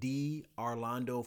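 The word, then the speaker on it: Darlando: You can find me all over social media at D Orlando Fortune. Now, my name is the letter D, Orlando, and Orlando is spelled like Orlando as in Orlando, Florida, but with an A.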